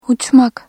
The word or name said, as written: uç-